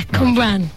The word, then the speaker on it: Cwmbran: Cwmbran. – I don't want to say it wrong, see. Cwmbran. – Yeah, Cwmbran.